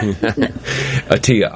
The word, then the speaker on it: Attia: What's the author's name? – Dr. Peter Attia. – Spell it. – A-T-T-I-A. – P-E-T-E-R. – What? – Atiyah. I-T-T-I-A. Sorry, it's A-T-T-I-A. – Atiyah. All right, ladies and gentlemen, Outlive by Peter Atiyah. A-double-T-as-in-Tom-I-A. Right? – That's it.